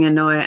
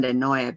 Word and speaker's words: Enoe: I was always using Anoia, actually, and for many years I thought, well, for the American public mind, it's hard for to understand Anoia because it's spelled E-N-O-E. It's based on the Spanish alphabet, and so it kind of gets lost sometimes.